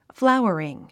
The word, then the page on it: flowering: fláu ə riŋ; フラウワリン